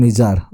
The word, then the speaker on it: Nijjar: It's pronounced, or it's spelled rather, as Nijjar. Let's call him Nijar, okay?